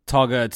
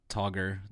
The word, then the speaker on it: Togga: Togger, T-O-G-G-A, not Togger, the way Ryan says it.